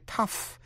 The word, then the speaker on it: tough: Chính tả lối Mỹ, P-L-O-W, plowman, người thợ cày, G-H cầm. O-U-G-H còn đọc là uff trong chữ tough, T-O-U-G-H, tough là cứng rắn, cương quyết hay là dai như trong chữ thịt. The steak is tough. Miếng thịt bò này dai quá. Hung bạo như đối với tụi côn đồ. Tough. GH trong trường hợp này phát âm là F. O-U-G-H còn phát âm là O trong chữ though là tuy nhiên, dẫu vậy.